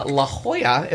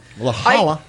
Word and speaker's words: La Jolla: So for my week, we went to my friend Nate and Jen's wedding in the beautiful and as of yet unexplored Central La Jolla, as we pronounce it. – La Jolla.